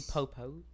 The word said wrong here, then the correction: Popoch: Is that how you say her name? Jenny. – She would slap you upside the head. – Dude, I know she would. – Her name is Jenny Popoch. – Popoch.